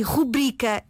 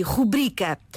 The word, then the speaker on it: rubrica: Nós só temos em português a palavra rubrica sem qualquer acento no U e o seu acento tónico está na sílaba bri, rubrica.